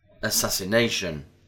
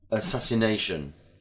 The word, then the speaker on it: assassination: Assassination. When we add the suffix ation, the stress doesn't fall on the second syllable, but on the penultimate syllable, the one before the last.